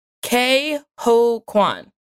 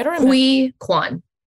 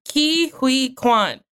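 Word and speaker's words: Ke Huy Quan: During the, okay, we have a pronunciation guide. K-ho Kwan. Okay. – Hui Kwan. – Jesus Christ. I'm illiterate. Like, it's written out. – The middle one was hard. I didn't know how to do hui. It's like really close together.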